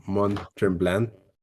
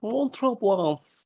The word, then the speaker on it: Mont Tremblant: Sí, pero pues eso fue ahí en, en Mont Tremblant y ya regresamos a. – Que lo estás pronunciando más mal. Es, es Mont Tremblant.